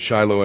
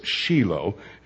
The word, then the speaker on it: Shiloh: They don't pronounce it Shiloh in Israel, it's Shiloh.